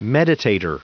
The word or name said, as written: meditator